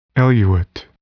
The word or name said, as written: eluate